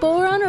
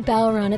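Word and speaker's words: bodhrán: It is pronounced Boron or Balron. It's spelled B-O-D-H-R-A-N, and it used to be something that you would winnow grain with.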